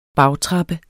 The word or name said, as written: bagtrappe